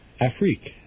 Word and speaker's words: Afrique: Now, how would you pronounce that word? – Afrique. Afrique. A-F-R-I-Q-U-E.